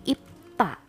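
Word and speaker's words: ipta (입다): Kalau di konsonan akhir bunyinya jadi seperti huruf P. Ya, jadi ipta. Tulisannya B, bacanya P ipta.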